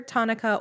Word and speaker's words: Tanaka: You know, in his name has three syllables. It's Ta-na-ka. So it could be Tanaka or it could be Tanaka. It really just depends on where you're putting the emphasis.